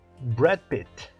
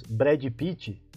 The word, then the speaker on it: Brad Pitt: Então a pronúncia correta Brad Pitt. Brad Pitt. E quando nós dizemos Brad Pitt, você viu o filme do Brad Pitt? É como se você estivesse dizendo Bradzinho Pittzinho. Ok?